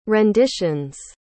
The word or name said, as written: renditions